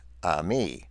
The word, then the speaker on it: eimi: The Greek word for am used in John 8 at the beginning of this article is E-I-M-I, pronounced ami, which means to be present, to exist, to happen.